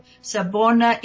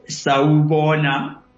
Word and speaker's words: Sawubona: Sabona Indiana. And is that right? Have I pronounced it correctly? – Sawubona Unjani.